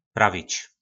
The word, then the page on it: Pravic: Po našimu